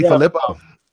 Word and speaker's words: DeFilippo: So, and I think they've noted too that him coming from the Giants, it helps because I think I always say his name wrong. Coach DeFlip. – Help him out. Help him out. – Oh, I say it wrong. – Just call him JDF. JDF. – Yeah. JDF. – DeFilippo. – DeFilippo, there it is.